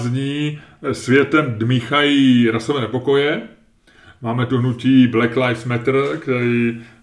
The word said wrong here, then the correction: Black Lives Matter: Tvoje výslovnost je šílená, teda bych chtěl říct. Black, ty jsi to opravdu vyslovil jako Black Lives Matter. Což je hrozný. to zní strašně. Black Lives Matter.